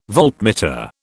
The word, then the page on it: voltmeter: British English